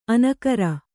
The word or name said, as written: ಅನಕರ